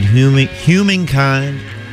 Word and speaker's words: humankind: Ramones started with Beat on the Brat and played Humankind. I do pronounce my H, humankind.